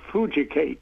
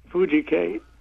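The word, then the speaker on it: Fooducate: The app is called Fujicate, and it's spelled food, and then it's one word, U-C-A-T-E, Fujicate.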